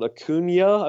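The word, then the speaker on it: Acuña: Ronald Acuna, I believe is the pronunciation I've been given at this point.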